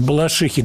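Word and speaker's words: Балашиха: Э, Дмитрий из, сейчас я посмотрю, из Балашихи, ударение хочу правильно поставить, из Балашихи Дмитрий задает такой вопрос.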